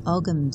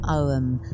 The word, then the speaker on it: Ogham: It's the Celtic Reiki course, which works with the Ogham symbols, or Ogham, depending how you want to pronounce it. Ogham or Ogham, there isn't a right way.